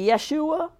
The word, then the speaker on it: Jeshua: I think we say Yeshua. It's spelled J-E-S-H-U-A by our author, which means Jehovah is salvation.